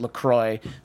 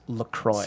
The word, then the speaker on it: La Croix: It's from somewhere in Middle America, and they pronounce it La Croix, so the official pronunciation is La Croix.